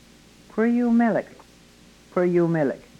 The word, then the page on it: kwryumelek: yurok